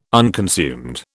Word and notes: unconsumed: British English

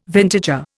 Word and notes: vintager: Indian

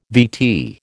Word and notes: VT: American English

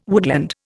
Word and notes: woodland: Indian

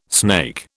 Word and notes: snake: British English

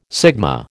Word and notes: sigma: American English

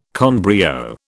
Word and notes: con brio: British English

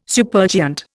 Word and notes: supergiant: Indian

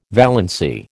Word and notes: valency: American English

